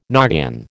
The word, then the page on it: noggin: American English